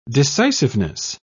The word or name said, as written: decisiveness